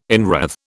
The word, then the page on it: inwreathe: British English